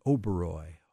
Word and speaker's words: Oberoi: The Oberoi Hotel. – Oberoi Hotel.